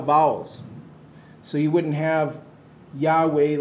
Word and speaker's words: Yahweh: That's Yahweh. That's how we pronounce it.